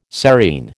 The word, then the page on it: serein: American English